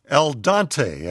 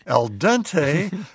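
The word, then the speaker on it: al dente: He writes, "Dear Zorba, on the show, you often refer to pasta as being done El Dante, as in Dante's Inferno. The correct pronunciation is El Dente, which is literally translated from Italian to mean to the tooth, as in dental.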